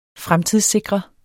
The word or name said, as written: FREMTIDSSIKREDE